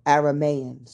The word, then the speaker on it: Aramean: She was Bethuel's daughter and the sister of Laban, the Armenian. The Aramean. Sorry, not Armenian.